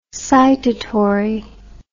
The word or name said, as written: citatory